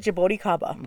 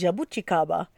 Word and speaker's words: Jabuticaba: So we always said Jabuticaba. – Okay, that one. – Until Brazilians came into the winery and said, "You are massacring that word." So it is Jabuticaba. Is how they say it.